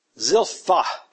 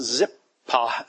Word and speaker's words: Zilpha: Zilpha. We got pronounced it Zip. Pa, some of you. It should be a F sound, PH sound.